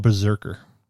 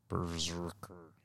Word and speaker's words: BRZRKR: It's called Berserker. But it's spelled Brzrkr. – Berserker.